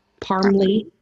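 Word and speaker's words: Parmly: Like Parmesan, but Parmley.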